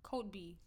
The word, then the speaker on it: Kotb: How the fuck do you say her last name? – Spell it. – K-O-T-B? – Code B. – Is that really how you say it? – I think so.